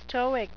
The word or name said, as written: stoic